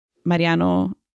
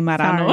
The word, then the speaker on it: Mariano: Dude, now I'm tripping because I feel like I'm going to say Marano. – Sorry, no, no. – You said it right. – I know I did, but in my head, it sounded like Marano.